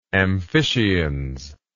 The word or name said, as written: amphiscians